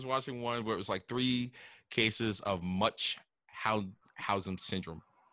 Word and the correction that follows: Munchausen: I'm saying that wrong, right? – Um, right. And before I even like preface, sir, I'm not gonna try it. – Yeah, it's like Munchausen syndrome by proxy, right? – Mm-hmm.